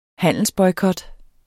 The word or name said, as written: handelsboykot